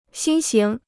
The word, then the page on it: 新型: xīn xíng